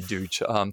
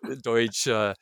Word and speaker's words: Duch: People pronounce Duch's name in various ways. I say Duch. – Deutsch. – So there's all kinds of ways. – Spelled D-U-C-H. – D-U-C-H.